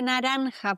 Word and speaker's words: naranja: Un zumo de naranja, por favor. Einen Orangensaft, bitte. Naranja. Super schwieriges Wort, weil das J, das wird ausgesprochen so wie ein CH, das muss im Hals kratzen.